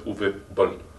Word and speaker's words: Uwe Boll: Kontroverzní bolus, protože, jak jste asi se dozvěděli, tak Uwe Boll, což je jeho oficiální výslovnost, že se musí říkat Uwe Boll, je německý režisér, režisér takových zvláštních filmů, jako je Far Cry třeba, nebo Dungeon Siege, nebo Blood Rain.